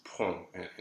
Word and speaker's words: prompt: So prompt in English, you pronounce it, but in French you don't. So it's prompt in French. And it looks very strange because it's way too many letters for just one sound.